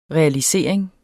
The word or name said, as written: realisering